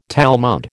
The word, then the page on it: Talmud: American English